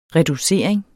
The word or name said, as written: reducering